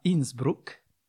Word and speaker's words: Innsbruck: Les Jeux Olympiques d'hiver à Innsbruck, on doit prononcer comme ça, puisque c'est en Autriche, et alors il n'y avait pas de neige.